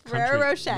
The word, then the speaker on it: Ferrero Rocher: What Ferrero Rocher.